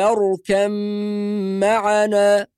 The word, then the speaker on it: اركب: معناه أن حرف الباء يلغى تماما ثم ننطق ميم مشددة بعد الكاف ولا بد من الغنة، فنقول يا بني اركم معنا بهذه الطريقة، يا بني اركم معنا ولا تكن مع الكافرين.